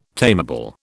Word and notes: tameable: British English

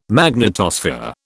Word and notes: magnetosphere: British English

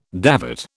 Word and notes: davit: British English

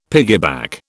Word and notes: piggyback: British English

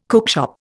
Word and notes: cookshop: Indian

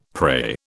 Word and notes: pray: British English

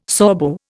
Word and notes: Sorbo: Indian

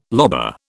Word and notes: lobar: British English

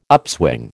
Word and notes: upswing: American English

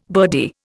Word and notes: birdie: Indian

